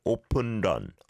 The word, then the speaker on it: 오픈런: 오픈런, 오픈런. Yeah, we don't have an R sound, so the leal is closest. 런, 런. Almost sounds like learn, but without the R.